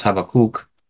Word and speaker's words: Habakkuk: So Paul uses applicational fulfillment to apply a principle from Habakkuk, or as Rabbi Dean says in the Hebrew, that would be pronounced Habakkuk. Reminds me of how you might pronounce something in Klingon if you're a Star Trek fan.